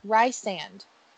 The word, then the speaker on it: Rhysand: Rhysand. How did I don't even know how I pronounced it. – You were saying it wrong, but it is Rhysand. Like Reese. Rhysand.